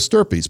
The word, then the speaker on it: stirpes: It's as simple as putting comma per stirpes, P E R S, and the new word S T I R P E S. Stirpes rhymes with something else.